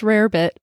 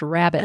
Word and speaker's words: rarebit: That's actually, it looks like it says rarebit, but it's pronounced rabbit.